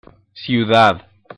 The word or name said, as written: ciudad